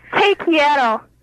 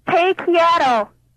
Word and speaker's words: te quiero: How do you say I love you in Spanish? – How do you say it? Te quiero. – It's what? – Te quiero.